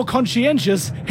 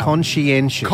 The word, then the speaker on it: conscientious: Conscientious. – Conscientious. – C-O-N-S-C-I-E-N-T-I-O-U-S. – That's correct! – Yeah! – Oh. Good guess. – That's four syllables.